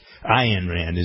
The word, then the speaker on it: Ayn Rand: I don't buy all of Ayn Rand stuff, but Ayn Rand is the way to pronounce it, I'm told.